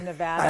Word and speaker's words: Nevada: And I applaud your pronunciation of Nevada.